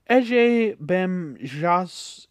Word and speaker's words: Ege Bamyasi: But without further ado, at number 454, we have the band Can, which I can pronounce, with Ege Bamyasi, which is the Turkish pronunciation, and I guess it's just a kind of okra.